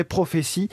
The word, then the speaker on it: Prophesee: Ça s'écrit à la fin, c'est S-E-E comme le verbe to see en anglais. Prophétie.